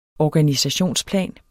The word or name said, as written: organisationsplan